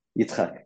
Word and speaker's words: Yitzchak: I'm afraid to pronounce the second name. Is it Yitzchak? – Yitzchak, yeah.